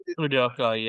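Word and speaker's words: Udoka: Udoku? Is that right? – Udoku, yeah.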